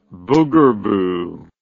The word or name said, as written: boogerboo